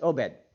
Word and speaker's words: Obed: Obed.